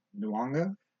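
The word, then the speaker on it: Nyong'o: And so, you know, for Miss, how do you pronounce Lupita's last name? – Nuanga. – There we go.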